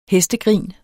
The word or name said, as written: hestegrin